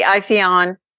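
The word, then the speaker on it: Ipheion: Is it Iphion or Iphion? – I'd say Iphion.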